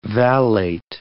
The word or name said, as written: vallate